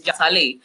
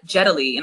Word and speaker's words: Jatali: So everyone says Jatali. And then, you know, I come here and they're like Jetali, and I'm like, who's that?